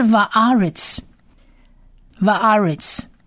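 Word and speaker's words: Va'aretz Va'aretz (וָאָרֶץ): 再我们看第二列的最后一个字，第三个字就是 Va'aretz Va'aretz，天和地的和地 Va'aretz Va'aretz Va'aretz 有四个字母，第一个字母是 Vav Vav Vav 呢，它在发音上是念 V V 的音，而下面那个 T 就是长 A，所以是 Va Va。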